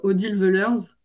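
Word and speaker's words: Odile Weulersse: Il y avait une autrice, Odile Wellers. – Oui. – Je sais pas si je dis comme il faut. – Oui, voilà.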